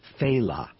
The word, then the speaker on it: Failla: His name is pronounced Failla. Failla. It's spelled F-A-I-L-L-A. So people go Failla. Failla. You know, but it's Failla.